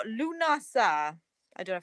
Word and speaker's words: Lughnasadh: according to what I've looked at, and I've got like a what do you call it when you have a pronunciation, and it does go Lughnasadh. – All right, then I said it right.